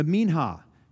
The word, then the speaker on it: Minha: Um, this is the minha chapter 2, M I N H A H, Minha is how you say that.